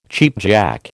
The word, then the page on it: cheapjack: American English